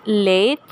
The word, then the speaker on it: Lethe: I believe it's pronounced Lethe, L-E-T-H-E.